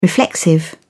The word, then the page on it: reflexive: British English